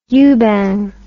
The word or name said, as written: yuban